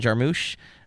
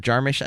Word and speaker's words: Jarmusch: We'd love to hear your thoughts on Coffee and Cigarettes, directed by Jim Jarmusch, or Jarmusch. I've heard it pronounced both.